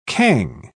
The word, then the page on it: cang: British English